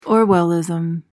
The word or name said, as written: orwellism